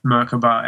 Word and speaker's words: Merkaba: It's kind of like tomato, tomato. – Yeah. Okay. – If you go to Hebrew, they say Merkaba, which is kind of...